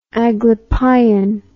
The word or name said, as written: Aglipayan